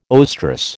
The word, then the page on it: oestrus: American English